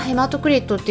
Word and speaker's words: ヘマトクリット: HCT っていうものでこれまあヘマトクリットって読む